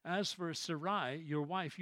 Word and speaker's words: Sarai: Sarai is how it was originally pronounced.